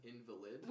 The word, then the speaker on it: invalid: Which did not know how to pronounce at all. – No, I misread it. I thought it said invalid. – I mean, yes. Yeah, and they're spelled this similarly, but it was just funny.